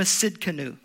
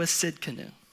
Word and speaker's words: Tsidkenu: The covenant name is Jehovah Tsidkenu. The T is silent. Jehovah Tsidkenu.